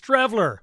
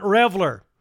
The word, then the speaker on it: Streveler: Oh, and you said his name wrong. It's Streveler, not Revler.